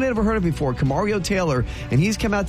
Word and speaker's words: Kamario: K Mario. Kamario. – Think of it that way.